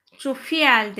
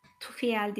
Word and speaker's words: Jofiel: Meltem Karanfil dişilik aktivasyonu için bu melekle ha Jofiel demiştim. Tufiel değil, Jofiel, baş melek Jofiel ile çalıştığınız zaman az önce söylediğim J ile yazılıp Jofiel diye yazılıyor. Jofiel diye okunuyor.